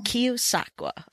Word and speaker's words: Keosauqua: Keosauqua.